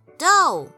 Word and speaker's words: do: do 这个音的念法跟母的路 do d o e 的发音是一样的，所以唱 do a deer a female deer。